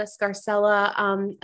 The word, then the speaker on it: Scarcella: I knew I was going to screw it up because I just thought of the Italian pronunciation. I was like, wait a minute, Scarcella.